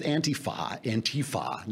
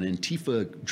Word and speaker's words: Antifa: this thing with Antifa. Now we got to put the accent on the T. It's on the wrong syllable.